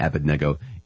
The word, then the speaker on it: Abednego: I always go back and forth with Abednego and Abednego. It's Abednego, I hear, because I believe the accent is on the third syllable.